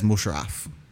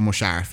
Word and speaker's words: Musharraf: a guy called Pervez Musharraf. – Okay. – Or Musharraf, I think is probably more how you put more emphasis on the R.